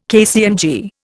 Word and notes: KCMG: Indian

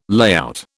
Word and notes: layout: British English

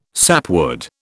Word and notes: sapwood: British English

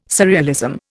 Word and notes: surrealism: Indian

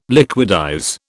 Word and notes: liquidize: British English

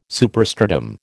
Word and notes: superstratum: American English

